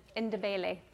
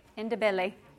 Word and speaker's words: Ndebele: and Ndebele. Ndebele. Sorry, said it wrong.